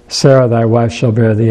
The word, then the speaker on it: Sarah: And this is Sarah. In Hebrew, they would accent the second syllable.